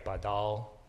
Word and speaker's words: badal: Verbs usually go like badal. This is the accent here. They're all long verbs, but the accent goes on the second syllable.